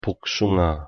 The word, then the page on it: 복숭아: Korean